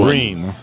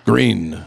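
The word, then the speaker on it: Gruene: It's spelled Gruen. Like the German spelling, but Bruin. They pronounce it Bruin. Green. – Green.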